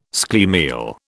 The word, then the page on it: schlemiel: British English